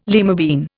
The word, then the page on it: lima bean: Indian